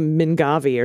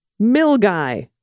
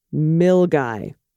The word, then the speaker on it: Milngavie: As a Glaswegian, I can tell you the correct pronunciation of Milngavi. Ah, remember we were like, it's probably Mingavi or something. Uh-huh. It's Milgai. All right. – There's a lot of silent letters in there.